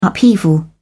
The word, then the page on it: upheaval: British English